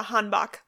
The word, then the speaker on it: hanbok: Yeah, I'm probably mispronouncing it, but a hanbok.